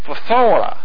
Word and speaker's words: phthora: we have the word, the Greek word for kill, phthora. You can spit on someone trying to say that correctly. Phthora, that's P-H-T-H-O-R-A.